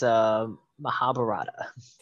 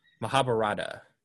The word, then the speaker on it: Mahabharata: I think it's Mahabharata. – Mahabharata.